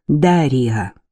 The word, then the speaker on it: Дарья: Дарья Янек произносит как Дарья. С ударением, слава богу, все в порядке.